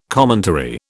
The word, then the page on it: commentary: British English